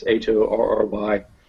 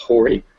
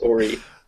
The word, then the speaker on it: Horry: Okay, so these other three are from Horry County, which is Horry. Usually improperly pronounced Horry, which is... – It's rude. – There could be some areas that are Horry, but it's pronounced Horry.